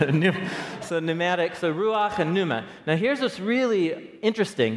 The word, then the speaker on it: pneuma: It's the word pneuma, pneuma, and the silent P right there, pneuma.